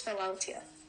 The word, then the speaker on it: Philautia: The type of love I have selected is Philautia.